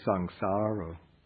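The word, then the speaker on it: saṃsāro: It should be sangsaro. The second A is a long A, sangsaro.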